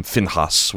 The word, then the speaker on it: Phinehas: Wright, has done some really interesting work on the lineage, or not like the spiritual lineage of Phinehas, or he calls him Finhas when he pronounces it.